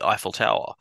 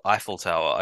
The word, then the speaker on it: Eiffel Tower: is it my pronunciation of Eiffel Tower? – エッフェルタワー。 – I've always called it Eiffel Tower. I don't know what the French pronunciation is. I guess that's the correct one.